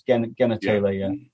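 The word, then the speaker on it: Genertela: I think someone said it should be a hard G anyway, so it's genitalia.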